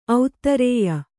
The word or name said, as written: ಔದಾರಿಯ